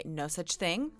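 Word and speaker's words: Nosaj Thing: I have blue this week, and my pick is a song called Aquarium by No Such Thing, who is a Korean-American record producer who lives in Los Angeles. Nosaj is spelled N-O-S-A-J, and it's just Jason spelled backwards because his name is Jason Chung.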